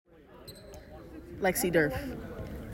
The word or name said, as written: Lexi Derf